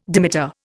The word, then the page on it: dimeter: Indian